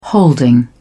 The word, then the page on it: holding: British English